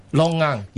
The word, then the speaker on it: longan: Longan. – Yeah. She says it looks like a little lychee. – Yeah, lychee. – Brown skin. – It's similar to lychee, but it's the function of longan actually is different from lychee,